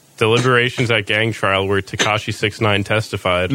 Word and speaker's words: Tekashi 6ix9ine: Oh, it tells you how to pronounce it. Tekashi 6ix9ine.